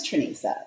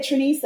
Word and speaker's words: Trenesa: My name's Trenesa. It's homemade, you know, Black people, you know, that's what we do. It's not that hard once you know it. You may not necessarily know how to pronounce it when you see it in writing, but once I say Trenesa, it's okay, let's move on.